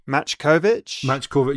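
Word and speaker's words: Machkovech: This time, apologies for pronunciation of surname, Sam Makovec or Makovec. I don't know how that your surname is pronounced. – Matchkovic. – Yeah, no, you might be right there. I think I've completely butchered it. Yeah, Sam Matchkovic.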